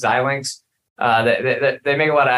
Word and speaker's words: Xilinx: I was always confused with it, but Xilinx is definitely the correct way.